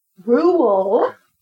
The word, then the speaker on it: rural: So our next story comes from rural. Rural. – You've got to say, yeah, and to say it properly, you've almost got to say it like Earl.